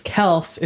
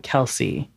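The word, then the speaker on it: Kelce: One of my other favorite things is Travis, and I'll say his last name, is Kels, is how they say it. But people call him Kelsey, a tight end for the Kansas City Chiefs.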